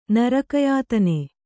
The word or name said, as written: ನರಕಯಾತನೆ